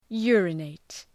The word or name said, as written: urinate